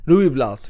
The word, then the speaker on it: Ruy Blas: För detta blev en succé Rublas. Ruiblas, förlåt, förlåt. Jag vill ha det spanska franska uttalet. Ruiblas.